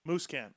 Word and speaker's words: Musquin: Musquin, okay.